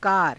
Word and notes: kaara: Hindi

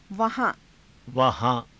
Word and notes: vahaa~: Hindi

